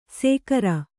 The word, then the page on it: ಸೇಕರ: Kannada; sēkara